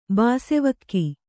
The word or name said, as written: ಬಾಸೆವಕ್ಕಿ